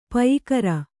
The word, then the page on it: ಪಯಿಕರ: payikara